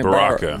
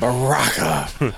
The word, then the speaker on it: Baraka: We got Sub-Zero, Scorpion, Sonya Blade, Scarlet, how you pronounce his name? – Baraka. Barack Obama. – Baraka. Raiden, Geras, and Shao Kahn.